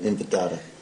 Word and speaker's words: Intifada: Now, for our listeners' sake, can you explain what an intifada? I never say it right. Intifada.